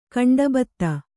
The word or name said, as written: ಗಂಡುಭತ್ತ